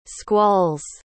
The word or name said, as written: squalls